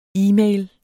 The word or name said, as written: e-mail